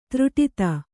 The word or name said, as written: ದೃಢತೆ